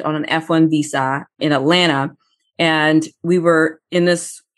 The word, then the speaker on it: Atlanta: So if people know, you don't say the T. If you're from Atlanta, you don't say the T. Atlanta.